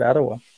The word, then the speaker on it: Aroha: No, actually, it's called Ottawa. Oh. Ottawa Cruisin'. It's spelled A-R-O-H-A.